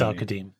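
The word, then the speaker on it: Al-Qadim: And did I say Al-Kadim in the intro? I did say Al-Kadim. Yes, it is Al-Kadim.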